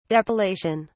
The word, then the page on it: depilation: British English